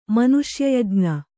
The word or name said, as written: ಮನುಷ್ಯಯಜ್ಞ